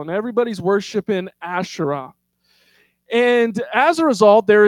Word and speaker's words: Asherah: And I'll also say Asherah because I don't like to say Asherah, but it's properly pronounced Asherah.